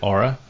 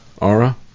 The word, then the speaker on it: Oura: Is that the right way to pronounce that? O-U-R-A. Do you guys know? – I think they pronounce it Aura. – Aura? Okay. Wall